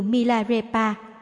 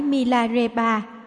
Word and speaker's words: Milarepa: Trong trường hợp các tên riêng kết thúc bằng chữ Pa thay vì dùng chữ Ba như Milarepa, không phải Milarepa và Rechunpa không phải Rechunpa.